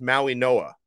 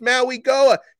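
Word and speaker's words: Mauigoa: Let me correct something, because one of our Hawaiian listeners pointed out to me that I'm pronouncing, most people are pronouncing their last name wrong. Even though it's spelled the way it is, it's Maui Noa, okay?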